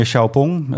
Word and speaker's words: Xiaopeng: Ja, de naam is van eigenlijk de grootste investeerder, meneer Xiaopeng. Zo spreek je het op zijn Chinees uit.